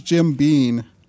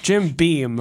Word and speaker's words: Jim Beam: How do you say that? Jim Beam? I always thought it was Jim Bean. – It's Jim Beam. But you should definitely say Jim Beam.